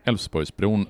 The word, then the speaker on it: Älvsborgsbron: Och den här lilla pojken säger Älvsborgsbron och då inser Torsten Henriksson att det göteborgarna kommer göra, det är att de hoppar över de här besvärliga konsonanterna i uttalet av Älvsborgsbron. Så det blir Älvsborgsbron som man beslutar att den här bron ska heta 1961.